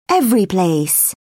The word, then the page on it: everyplace: British English